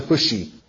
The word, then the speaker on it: Hushai: And after he uttered these words, the narrator tells us a man by the name of Hushai arrives, or some pronounce it Hushi.